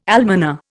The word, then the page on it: almoner: Indian